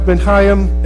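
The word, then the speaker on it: Ben Chayyim: This text is also known as the, it looks like Ben Chaim, it's pronounced Ben Chaim, edition of the Hebrew Bible.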